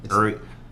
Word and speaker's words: Uri: Oh, you mean Uri? – Oh, sorry. Uri. – It's double O-R-I.